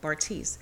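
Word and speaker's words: Bartise: And it's so funny because it seems like with Bartice, there it is, I got his name right, Bartice.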